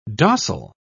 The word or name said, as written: docile